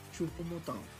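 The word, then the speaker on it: Choupo-Moting: lo ocupas, pero creo que era mejor meter a, a ahí a, Sané con este Chupometán, o como se diga su, su nombre, y a Nabri. ¿Cómo es? – ¿Cómo se dice? – Chupomotán. Chupomotán.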